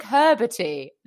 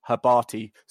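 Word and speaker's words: Hrbatý: Dominic Herberti, all you need to know is that you're saying his name wrong, because his name his name is actually pronounced, I believe, Dominic Herberti.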